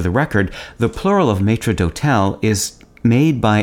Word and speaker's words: maître d'hôtel: In French, it's pronounced maître d'hôtel.